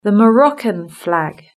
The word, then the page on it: the Moroccan flag: British English